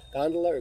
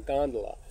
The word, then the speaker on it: gondola: Yeah, I'm told the root word, I get asked all the time, what's the correct pronunciation, gondola or gondola or gondola? Gondola is, Americans tend to say gondola, Europeans tend to say gondola.